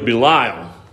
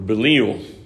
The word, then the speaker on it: Belial: In the Hebrew, it's actually a man of Belial, or Belial, depending on your pronunciation.